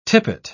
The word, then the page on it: tippet: British English